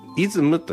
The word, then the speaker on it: ISM: ISM と書いて、これなんて読むのかなと思っていろいろ調べたら、イズムと読むらしいんですね。